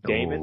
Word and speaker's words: Daemon: And Demon is spelled D-A-E-M-O-N. Like Damon.